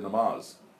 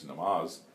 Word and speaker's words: namaz: Yeah, namaj. – Namaj, okay, yeah, that's the most Bengali pronunciation I've heard, yeah.